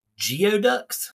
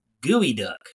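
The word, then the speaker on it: geoduck: And they're the word looks like geoducks. – Geoducks. – Like a rock and a duck, geo, but it is not, and it's actually not even pronounced that way. It's gooey duck.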